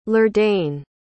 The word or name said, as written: lurdane